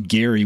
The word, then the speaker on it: Gerry: The name comes from Elbridge Gerry, which I think was actually pronounced Gary with a hard G.